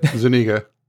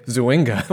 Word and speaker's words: Zuniga: Zuniga. Zuniga, thank you. I always I always want to say Zuinga, which I believe I did on a previous Zwinga.